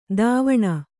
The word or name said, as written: ದಾವಣ